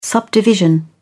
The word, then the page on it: subdivision: British English